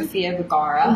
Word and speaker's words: Sofia Vergara: Sofia Vergara.